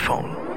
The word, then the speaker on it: Phaune: It's spelled P-H-A-U-N-E. But it's pronounced phone.